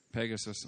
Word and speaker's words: Pegasus: And in the Manas, near the end of the Manas, is a story about Pegasus, how do you say it in English? Pegasus, okay. Pegasus.